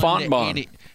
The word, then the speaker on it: Fontbonne: Fontbonne. – Fontbonne. – Yeah, they beat Fontbonne. – That sounds like a cinnamon roll. – It's in St. Louis. – Fontbonne.